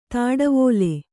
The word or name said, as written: ದೋಟಿವಲೆ